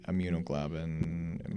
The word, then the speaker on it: immunoglobulin: And then we had to get the immunoglobulin, am I saying that right? – Yeah.